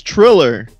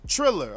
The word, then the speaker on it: Triller: Release, and the source is Thriller, not pronounced with an H. So I guess it's Tiller. – It's Triller. – Oh, Triller. All right.